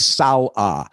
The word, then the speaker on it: Saleh: Now, the Detroit media maven Dennis Dale McClain had mentioned Robert Saleh here Monday as a candidate for the latest head coach of the FLs. But of course, Denny, as this is what butchered the name, listen up, it's Saleh.